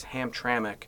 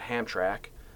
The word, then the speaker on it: Hamtramck: I thought that the second M was silent, but it isn't. So it's Hamtramck, not Hamtrak.